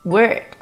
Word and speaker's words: word: World, word, world, word，这两个单词的读音应该是很多同学的老大难问题，很容易就读混了。长得也很像，只差一个字母 l，读音自然也就只差一个模糊舌侧音。 World, world 是世界，word, word 是单词，要注意区分哦。